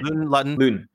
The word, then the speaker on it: Luton: Like Luton? I forget how you say his name. – Luton. Yeah.